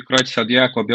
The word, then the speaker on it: Creutzfeldt-Jakob: É, Creutzfeldt-Jakob, tanto faz.